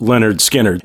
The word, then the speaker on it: Lynyrd Skynyrd: The album title is kind of clever. It's in parentheses, and it says pronounced Lynyrd Skynyrd.